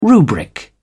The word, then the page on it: rubric: British English